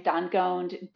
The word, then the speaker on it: Dangond: Martins, M-A-R-T-E-N-S, and last name Dangond, D-A-N-G-O-N-D, and on Instagram, I am B Dangond.